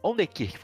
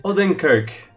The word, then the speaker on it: Odenkirk: Vamos lá, o primeiro, o primeiro indicado foi o Bob Old, Onder, Onderkirk. Falei errado, desculpa, gente, fãs de Bob. – Odenkirk.